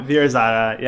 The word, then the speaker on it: Veer-Zaara: Yeah, Veer-Zaara, yeah.